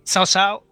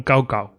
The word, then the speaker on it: Cao Cao: Es, es que dicen sao sao y hay a veces que dicen soso o según les apetezca, porque entre que después con las diferentes pronunciaciones del chino y dialectos aquí regionales y tal, ni entre ellos se, ponen de acuerdo. Bueno, entonces utilizamos la pronunciación eh, española del pinyin, ¿no? Eh, Ricardo, quedamos con Cao Cao.